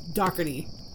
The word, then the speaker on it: Daugherty: Now, I do have the proper pronunciation for her last name, but I can't promise that I will be correct every single time because, you know, when you see her last name, the first thing that I see is Daugherty or Daugherty, but it's actually just Daugherty. So Daugherty is the correct pronunciation here.